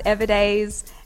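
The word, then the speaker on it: Everdayz: And it's pronounced Everdays. – And it's with a Z.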